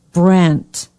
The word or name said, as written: brant